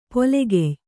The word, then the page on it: ಪೊಲೆಗೆಯ್: pole gey